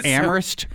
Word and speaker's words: Amherst: So Amherst, Mass, is no H.